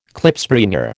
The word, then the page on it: Klipspringer: American English